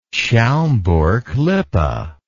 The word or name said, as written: schaumburg lippe